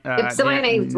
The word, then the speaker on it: Ypsilanti: Congresswoman, I think the last time around, you talked about being out in Ypsilanti. Am I pronouncing it right? – Ypsilanti, Near Ann Arbor, and just seeing most of the houses having Trump signs.